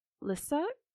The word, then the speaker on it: Lisieux: In 1927, she believed she had been visited by St. Teresa of Lisieux? This is where I was mispronouncing. L-I-S-I-E-U-X. Lisieux.